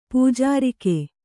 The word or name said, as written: ಪೂಜಾರಿಕೆ